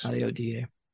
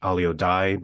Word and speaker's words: Alio Die: Alio Die. – Alio. – Alio. – Yeah, it's, lots of people mispronounce it as Alio Die because it's just D-I-E. – D-I-E. – But it's Latin, Alio Die.